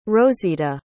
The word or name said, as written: rozita